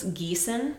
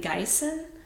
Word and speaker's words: Gießen: So the word was Gießen, like the town in Germany, the city. And I guess he was pronouncing it Gießen.